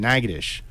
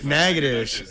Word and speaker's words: Natchitoches: She was born in Natchitoches. – Okay, that so actually counts. – It, and I said it counts. Right. And I said it right. – Yeah, Natchitoches counts. It's just fucked. – Natchitoches.